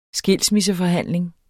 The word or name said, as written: skilsmisseforhandling